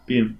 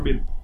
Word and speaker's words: Bean: Äh, hier äh, Sean, Sean, äh, Sean Bean, sagt, spricht man das aus, gell? Bean, ja, Sean Bean.